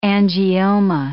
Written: angioma

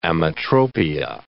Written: ametropia

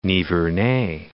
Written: Nivernais

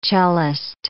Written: cellist

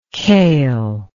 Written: kail

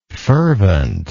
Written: ferventness

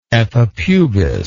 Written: epipubis

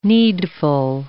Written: needful

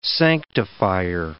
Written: sanctifier

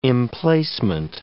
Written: emplacement

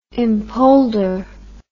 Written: impolder

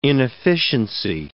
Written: inefficiency

